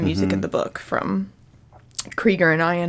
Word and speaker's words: Krieger: Henry, is it Krieger? – Yeah, Krieger and Tom Ian.